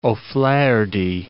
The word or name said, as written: O'Flaherty